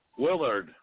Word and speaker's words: Willard: We were supposed to have Bill Lillard. Did I pronounce his last name? Huh? – Willard, like you know the movie with the rats. Willard. – Oh, Willard.